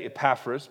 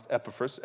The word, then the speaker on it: Epaphras: Verse 23 of Philemon, Epaphras, some say Epaphras, but generally it's pronounced Epaphras.